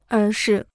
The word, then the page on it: 而是: ér shì